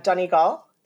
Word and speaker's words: Donegal: Leganí, you got the first one right, and that's impressive. And Donegal. So well done.